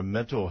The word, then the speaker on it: Mintle: Your last name is spelled M-I-N-T-L-E. – Oh, good. – And you're a mental health professional. – I am. I'm Dr. Mental. – Dr. Mental, like a mint.